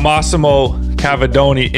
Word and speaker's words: Massimo Cavedoni: All right, we got Massimo Cavedoni in the building, man.